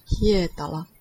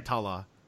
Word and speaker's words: Hietala: Hietala. – What do we got? Hietala.